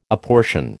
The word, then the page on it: apportion: American English